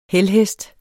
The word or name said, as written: helhest